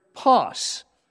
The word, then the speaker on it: pas: And he uses the Greek word pas. We would phonetically spell it P-A-S.